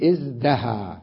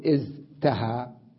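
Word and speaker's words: ازدهى: أقول إزدها، لا أقول إزتها، ثقيلة التاء جدا، إزدها أقرب إلى النطق وإلى سهولته.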